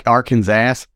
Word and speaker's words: Arkansas: states that Arkansas should be pronounced in three syllables with the final S silent, the A in each syllable with the Italian sound, and the accent on the first and last syllables.